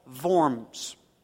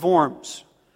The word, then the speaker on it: Worms: The city in which they invited him to come to this diet was in a city, a German city called Worms. Okay? Now, in German, you pronounce a W with a V. And so actually what they invited him to come participate in was the Diet of Worms.